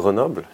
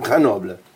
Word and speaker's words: Grenoble: The first Always Believe moment that I want to talk about is Grenoble. – Mm-hmm. – Is that okay for pronunciation? – Yeah, Grenoble. Yes. – It's okay. – Yeah, good. – Grenoble.